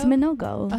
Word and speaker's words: Minogue: Is that how you pronounce her last name? – I feel like that's not how you pronounce the last name. – You know, yeah, meaning I don't think that's right either. But I'm gonna say it like Minogue. – No, it's Minogue.